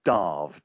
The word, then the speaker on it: Damh: And it's pronounced Darv, D-A-R-V. The M-H sound is a V sound.